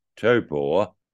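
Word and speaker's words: Taupō: The Taupo track is looking like where supercars will return to New Zealand next year. A top level supercars delegation visited the circuit, more correctly pronounced as Topor, and as well as finalising a submission to New Zealand major events for support, they had a good look at the track.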